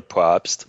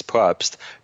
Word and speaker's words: Probst: My LinkedIn profile is very detailed, and I'm simply at Emmanuel Probst. Emmanuel is spelled with two M's, E-M-M-A-N-U-E-L, and my last name is Probst, P-R-O-B as in boy, S T.